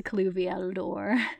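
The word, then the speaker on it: Cluviel Dor: But having listened to the audiobook this time, I remember it in my head as Cluveldor, so I'm glad that it's properly pronounced in the audiobook. Because in my head, I was like, where's the Cluveldor? Where's the Cluveldor? Here it is. Here's the Cluviel door.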